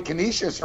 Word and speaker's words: Canisius: Yeah, you know, I can tell you Canisius are on a 9-game win streak on the ice, NCAA hockey.